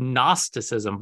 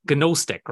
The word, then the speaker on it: Gnosticism: It's called Gnosticism, but it's actually has a G, so the G is silent. So Gnostic, right? – it's Gnosticism.